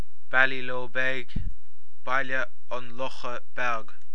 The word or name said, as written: Baile an Locha Beag